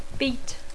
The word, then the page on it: beat: English